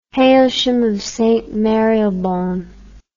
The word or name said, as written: Hailsham of St Marylebone